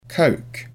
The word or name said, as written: coke